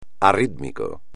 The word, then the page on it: arrítmico: espagnol